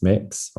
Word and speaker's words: Mx: And I was like, well, as a non-binary person, I would prefer it's if it's MX, which is pronounced mix or mix.